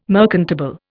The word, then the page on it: merchantable: Indian